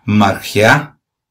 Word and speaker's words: margea: También la palabra 47-74, siempre la concordancia de Strong y pronunciada margea, M-A-R-G-E-A-H, margea, puede definir un refrigerio o un reposo.